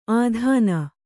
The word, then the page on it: ಆಧಾನ: ādhāna